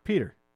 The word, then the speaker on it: Petr: The last name is Yan, Y-A-N. – Yeah. – His first name is P-E-T-R. – Peter. – Is it Peter? – Yeah. Okay. Yeah, that's like a Russian spelling of Peter.